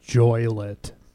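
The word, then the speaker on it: Joliet: This town we're in, it's pronounced Joylet. Just so you know. Anybody in Illinois knows that.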